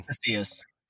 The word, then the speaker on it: Casillas: Casillas.